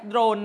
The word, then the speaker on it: โดรน: ก็คือออกเสียงดอกับรอควบไปเลยนะรู้จักโดรน